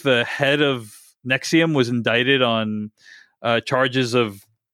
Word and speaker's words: NXIVM: And it's about this thing called Nexium, which is spelled NXIVM, but it's pronounced Nexium like the antacid drug.